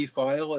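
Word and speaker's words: Pfeil: And when we were there for the 50th anniversary of the 69 team in 2019, Bobby File, as much as anybody else, was one of the honored guests. And he lives on, not only for the silent P, but for the loud presence.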